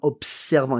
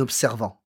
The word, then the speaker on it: observant: Donc, ce n'est pas en observant, but en observant. It's got to be a strong S.